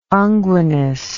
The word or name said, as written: unguinous